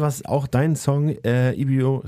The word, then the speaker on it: Ibibio: Mich hat heute am meisten gekriegt, irgendwie ein Song zum Tanzen, golden, warm, klebrig, Honeybee von Ibibio Sound Machine.